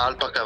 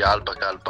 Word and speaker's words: Alpaca: Allora, come lo diciamo questo nome? – Ma Alpaca va benissimo. Va bene. Beh, l'abbiamo sentito sì, pronunciare in diversi modi. Alpaca va bene uguale.